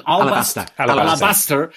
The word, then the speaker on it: alabaster: who will carve in Alabastrian. How do you say it? – Alabaster.